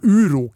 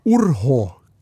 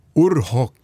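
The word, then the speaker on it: Urho: Vi levde jo i Norge i massevis av år med en finsk president som heter Uro Kekkonen. – Ja. Han heter Orho Kekkonen. Orho Kekkonen.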